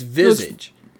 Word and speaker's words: Visage: No, it's not. It's Visage. It's really simple. And actually, if you do a Google search for pronunciation of Visage and you tell Google that you want to hear the French pronunciation, the British pronunciation, the Dutch pronunciation, it's always Visage.